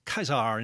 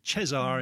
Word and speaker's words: Caesar: You know, it's pronounced Caesar in classical Latin and Cesar in medieval or church Latin. But, of course, we say Caesar.